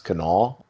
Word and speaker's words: Kanawha: Uh, you know that it's pronounced Kanawha County.